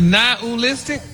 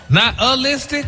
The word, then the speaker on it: nihilistic: Nihilistic? – Wow. – Stop putting the ooh in there. – Nihilistic? – There you go.